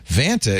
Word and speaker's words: Vanta: I like how at the top of the ad they say, Vanta is pronounced Vanta.